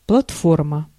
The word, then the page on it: платформа: /plɐtˈformə/; platforma